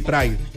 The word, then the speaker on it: Pride: ¿Pride? – Sí, Pride.